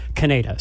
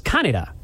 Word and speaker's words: Kaneda: Main character in the original English dub is Kaneda, so it's Kaneda. But Japanese wise, you would normally put the stress on the first character or on the first syllable, which is what they do in the remake. So it's Kaneda, but it sounds so much like Canada, I can't get around that.